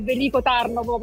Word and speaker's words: Veliko Tarnovo: Non ne ho idea, però vi posso dire con certezza che siamo a Veliko Tarnovo. Ah. Ho sbagliato sicuramente l'accento, perché è Veliko Tarnovo, però insomma noi qua ci divertiamo un po' a sbagliare tutti gli altri.